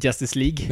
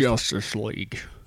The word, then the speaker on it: Justice League: Eh, vad svenska lät jag Justice League? Nej, jag tycker det var ganska bra uttal faktiskt. Justice League.